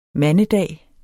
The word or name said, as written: mandedag